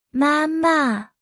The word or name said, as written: 妈妈